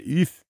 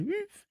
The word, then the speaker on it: oeuf: And he said, Oeuf. And I said, how? And he said, Oeuf. And he started making subsequently smaller and smaller noises with a vague F sound at the end.